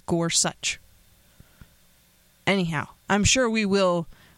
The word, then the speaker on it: Gorsuch: You're listening to The Friddle Show, and we are going to start, of course, talking about Justice Gorsuch. I know it is such. The end of the name is such, but I don't know how you put them together. It's hard to say Gorsuch. I guess Gorsuch.